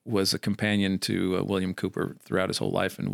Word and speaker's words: Cowper: I mean, like highly recommend a biography by a gentleman it's a biography of William Cooper. It's spelled C-O-W-P-E-R. He wrote hymns with Isaac Newton.